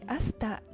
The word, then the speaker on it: hasta: まずね、私がマニアナランディアでも、このサラッとスペイン語でも最後に言っているアスタラプロキシマなんですけれども、これはね、また次回にという意味の、お決まりの文句なんですね。で、アスタのところが、までという意味の前置詞です。で、これまた発音されない H が最初についていて HASTA と書きます。